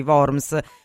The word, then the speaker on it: Worms: Sì, fatto bene a rimanere sul vago, in realtà la pronuncia corretta è proprio quella alla tedesca, quindi Worms, W letta come una V, ma non importa perché molte persone poi si sbagliano, quindi ormai ci sono abituata fin dai tempi della scuola.